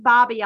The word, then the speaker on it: Bobbie: But if you want to follow me on social media, I'm at the Bobby Oliver on Twitter and Instagram. And my name is spelled B-O-B-B-I-E because I am a girl.